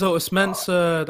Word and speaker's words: Otis Mensah: So my name is Otis Mensah.